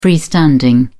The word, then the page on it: freestanding: British English